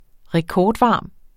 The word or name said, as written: rekordvarm